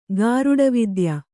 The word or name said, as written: ಗಾರುಡವಿದ್ಯ